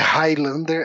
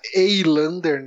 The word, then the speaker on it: Eilander: da família Highlander. Ah, não é Highlander, é Eilander, né? Se escreve.